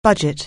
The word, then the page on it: budget: anglais